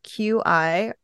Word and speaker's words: qi: Qi, which is sometimes spelled Q-I or sometimes spelled C-H-I, but it's pronounced qi, like C-H-E-E, refers to life force energy.